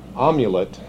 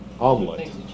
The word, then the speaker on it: amulet: use the name as a magic amulet. Is that how you say that? Omelet. No, it's a magic, how do you say it? Amulet.